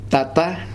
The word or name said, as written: tata